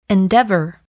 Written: endeavor

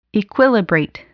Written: equilibrate

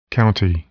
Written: county

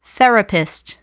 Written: therapist

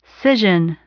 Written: scission

